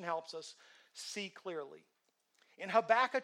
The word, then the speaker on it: Habakkuk: It's H-A-B-A-K-U-K. I was like, it's crazy. Habakkuk, that's how you say it.